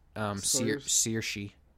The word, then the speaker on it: Saoirse: Her name is Saoirse Ronan. Yeah. – Yeah, but it's pronounced Saoirse.